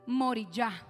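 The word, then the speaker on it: Moriah: Y el monte Moria se pronuncia Moriyá.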